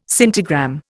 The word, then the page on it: scintigram: Indian